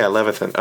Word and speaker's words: Levithan: Is it? Yeah, Levithan.